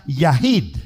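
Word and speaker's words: Yahid (יחיד): Y hay otra palabra que se pronuncia Yahid.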